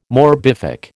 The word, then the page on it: morbific: American English